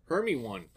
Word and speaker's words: Hermione: It was Hermione for a long time.